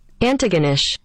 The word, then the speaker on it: Antigonish: Antigonish.